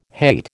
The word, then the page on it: hate: American English